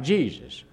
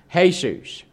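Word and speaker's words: Jesús: And if you ever see it spelled out, it looks like Jesus. But the Espanol pronunciation is Jesus.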